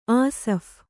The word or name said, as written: ಆಸಫ್